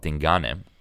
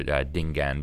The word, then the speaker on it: Dingane: And there was a Zulu king called Dingane, or Dingaan, depending on what pronunciation you use.